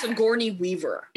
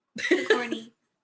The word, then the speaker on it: Sigourney Weaver: Her name is Sigourney Weaver. – Sigourney. I said, I didn't want to butcher her name. Sigourney.